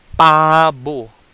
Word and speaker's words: pabu: So turkey in Cebuano is called pabu pabu.